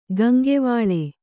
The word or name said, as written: ಕೊಂಕುವಿಲ್